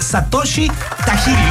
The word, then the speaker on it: Satoshi Tajiri: nace Satoshi Tajiri. ¿Lo dije bien? – Sí.